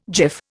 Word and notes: jiff: Indian

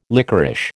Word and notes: lickerish: American English

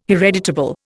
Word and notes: hereditable: Indian

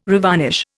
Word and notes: revarnish: Indian

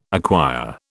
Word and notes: acquire: British English

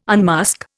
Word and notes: unmask: Indian

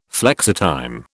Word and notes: flexitime: British English

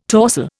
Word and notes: torsel: Indian

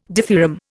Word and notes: dithyramb: Indian

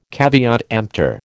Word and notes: caveat emptor: American English

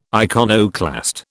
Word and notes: iconoclast: British English

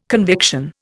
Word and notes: conviction: Indian

